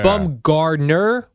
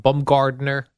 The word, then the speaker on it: Bumgarner: Noah Syndergaard versus Madison Bumgarner. – Yeah, Madison Bumgarner. – Yeah. – Is it Bumgarner or Bumgard? – There's no D.